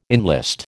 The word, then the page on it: enlist: American English